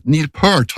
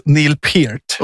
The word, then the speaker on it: Neil Peart: Neil Peart har jag hört. – Ja, det är Killinggängets fel. Det ska uttalas Neil Peart.